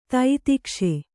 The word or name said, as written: ತಿತಿಕ್ಷೆ